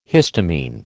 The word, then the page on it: histamine: American English